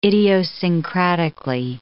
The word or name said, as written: idiosyncratically